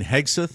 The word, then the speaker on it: Hegseth: Hayseg and all the dignitaries, where you then saw big crowds outside of that. – You mean Hegseth? – So, yeah. Well, yes. Did I say it wrong? – Hegseg.